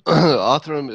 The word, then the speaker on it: Othram: One of the interesting things I wanted to ask you about is I believe Othram has started a database where you can voluntarily have your DNA stored there for crime solving purposes. Am I saying that right? – Yes.